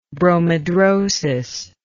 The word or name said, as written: bromidrosis